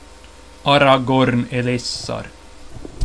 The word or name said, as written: Aragorn Elessar